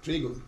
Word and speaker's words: Trigo: And I'm going to spell A-T-A-N-A-C-I-O. Yeah, Trigo, T-R-I-G-O.